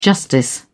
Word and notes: justice: British English

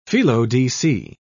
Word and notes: felo-de-se: British English

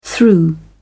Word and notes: through: British English

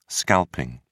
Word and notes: scalping: British English

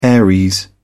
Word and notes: aries: British English

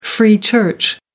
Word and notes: free church: American English

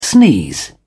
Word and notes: sneeze: British English

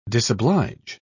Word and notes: disoblige: British English

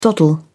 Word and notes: doddle: British English

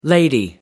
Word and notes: lady: American English

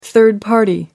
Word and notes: third party: American English

